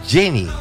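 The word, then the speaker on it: Jeanny: La pronunciación es Jenny, el nombre de la protagonista de esta canción.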